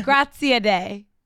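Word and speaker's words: Graziadei: How do you say his last? – Grazia Day. – Grazia Day? – Apparently, from my DMs.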